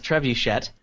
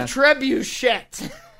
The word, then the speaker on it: trebuchet: That's how it's pronounced, isn't it? – I'm pretty sure. – Mm-hmm. – The Trebuchet. – That's it's French. – It's... It's what it sounds like when an Anglo tries to speak French.